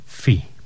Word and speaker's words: Phi: Za nami zespół Afodel, a przed nami grupa, o której wspominałem na samym początku naszego dzisiejszego spotkania, grupa FI. Pisze się to P-H-I.